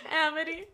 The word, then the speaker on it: Amity: Amity.